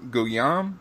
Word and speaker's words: Guillaume: Robert Gu- Guillaume is that how you pronounce his name? – Guillaume. Oh, Guillaume. Okay, Guillaume. Okay.